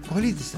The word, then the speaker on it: Cojolites: Con una banda llamada los Cojolites, así se pronuncia, porque es en Nahuatl.